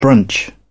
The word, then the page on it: brunch: British English